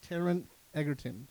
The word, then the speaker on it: Taron Egerton: Edgar? Did I say it right? Edgar? Egon, Mac Daddy. Anyway, the guy from Kingsman. Yes. I'm going to say it wrong until I say it wrong. Taron. That's it. Taron Egerton.